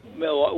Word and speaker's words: Milwaukee: I've never been to Milwaukee. Well, actually, but that's a whole different story. But no, I didn't. – You know, it's pronounced Milwaukee. That's the Native American tribe that it's named after.